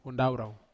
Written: hundaurau

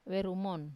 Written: verumon

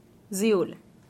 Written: zuill

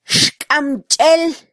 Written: xkamchel

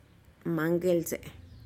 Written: mangelze'e

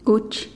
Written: kuch

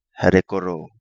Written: Herekoro

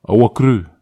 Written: ahu akry

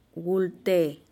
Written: gulteh

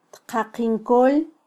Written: tqaqinkol

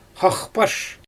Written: xaxpaʃ

